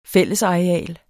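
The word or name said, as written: fællesareal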